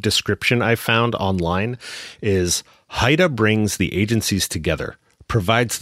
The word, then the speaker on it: HIDTA: HIDTA is how we pronounce the acronym that stands for High Intensity Drug Trafficking Area.